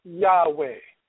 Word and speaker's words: Yahweh: The Hebrew letters are Yod-Heh, Vav-Heh. We pronounce it Yahweh.